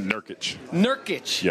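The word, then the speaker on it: Nurkić: Nurkic. Am I saying his name right? – Nurkic. Yes.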